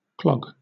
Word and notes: clog: British English

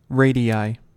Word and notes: radii: American English

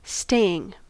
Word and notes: staying: American English

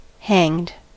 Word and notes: hanged: American English

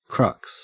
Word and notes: crux: Australian English

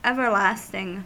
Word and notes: everlasting: American English

